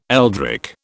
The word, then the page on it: eldritch: British English